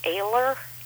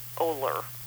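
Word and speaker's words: Oehler: He's mispronouncing it. It's Ayler, not Oler. – Oh, it's Walter Ayler. – Walter Ayler. – O-I-L-E-R. – Even though it's spelled with an O, O-E-H-L-E-R, it's pronounced Ayler.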